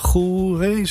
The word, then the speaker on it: Goeree: Middelharnis, dat is het eiland Goeree, zeg ik dat goed? – Ja, oké, heel goed.